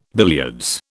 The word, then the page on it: billiards: British English